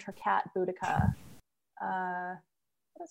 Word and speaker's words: Boudica: I say Boudica. – The Boadicea Club is Boadicea. Okay. – Yeah. – But there are several different pronunciations. So like, in different times, eras in history, they've called it Boudica, Boadicea.